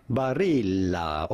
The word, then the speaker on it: Barilla: We are looking at how to pronounce with the typical Italian pronunciation the name Barilla.